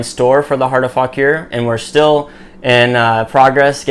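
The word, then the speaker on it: Fauquier: Here's a new mug that came in with the Falk Here, Virginia, and on the back it's pronounced Falk Here.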